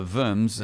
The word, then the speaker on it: Worms: The Worms, you know, and so German, the W is pronounced with a V, you know.